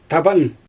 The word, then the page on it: taban: Xyzyl